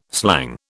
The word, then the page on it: slang: British English